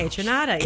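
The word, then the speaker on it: Kaytranada: How do you spell it? – It's K-A-Y. – K-A-Y. – T-R-A-N-A-D-A. – Uh-huh. Oh, spelled like it sounds. – Kejranada. Kejranada.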